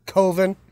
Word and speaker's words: Coven: It's pronounced Coven.